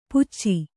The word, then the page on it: ಪುಚ್ಚಿ: kannada